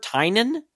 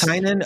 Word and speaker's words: Tynion: I think that's I've heard Tynan. I it's always Tynan because I think like Tiny Onion is kind of like the I think it's like the name of his Substack or something.